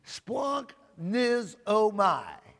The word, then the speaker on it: splanchnizomai: And if you're taking notes, splanknizomai is spelled S-P-L-A-N-C-H. N-I-Z-O-M-A-I, splanknizomai is how it's pronounced.